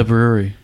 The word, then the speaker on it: Bruery: It's spelled like B-R-U-E-R-Y or something. – Wow. – The Brewery.